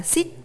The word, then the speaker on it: sit: Trong tiếng Việt chúng ta có chữ là sờ y tờ sắc đọc là sit, sit. Tuy nhiên trong tiếng Anh động từ ngồi đọc là E, sit, sit và chữ chỗ ngồi danh từ đọc là seat, seat.